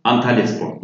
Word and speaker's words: Antalyaspor: So, Al-Anispor? – Antalyaspor, yeah.